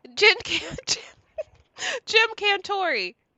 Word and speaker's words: Jim Cantore: Jim Cantalupus. What would you say? – Jim Cantori. – Jim Cantoria. – Yes. – Cantori. Yeah. I keep adding a little extra syllable.